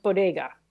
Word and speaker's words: bodega: Also, in the same sentence, she mispronounced bodega. Like, how insulting. – Mispronounced it so bad, I couldn't tell what she was talking about. – She pronounced it Buttigieg. – Bodega. Come on.